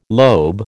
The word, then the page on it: lobe: American English